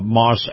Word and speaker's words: Maus: Now, if you love the feel of a high-end SUV, you're going to love Moss Acura. That's spelled M-A-U-S.